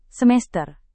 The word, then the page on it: semester: Indonesian